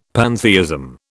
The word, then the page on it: pantheism: British English